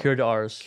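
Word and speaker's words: Curé d'Ars: St. John Marie Vianney, known as the, how do you pronounce it in French? – Cure d'Ars.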